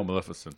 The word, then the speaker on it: Maleficent: Probably, I never saw him, but I think I don't even know how to pronounce it, Maleficent. – Oh, Maleficent.